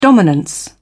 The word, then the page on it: dominance: British English